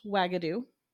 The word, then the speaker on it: Uagadou: It's actually spelled U-A-G-A-D-O-U, but it is pronounced Wagadu.